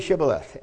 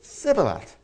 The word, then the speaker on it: Shibboleth: And they said to him, "Please say Shibboleth." And he said, "Sibboleth," because he could not manage to pronounce it right.